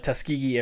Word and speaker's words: Tuskegee: Tuskegee Airmen.